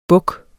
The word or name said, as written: buks